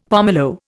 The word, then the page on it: pomelo: Indian